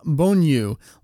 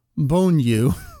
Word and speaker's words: Bonyu: So the character's name, Bonyu, likely comes from Bonyu, which means breast milk It's read the exact same way. It's a long U and an elongated U sound at the end of the bone U.